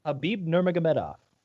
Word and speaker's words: Khabib Nurmagomedov: Khabib Nurmagomedov.